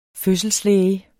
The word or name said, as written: fødselslæge